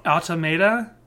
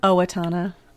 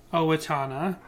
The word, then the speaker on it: Owatonna: Automata? Autotomia? – Owatonna. – Owatonna. Totally didn't just play a couple seconds of the song and then edit that out so that we knew how to pronounce Owatonna.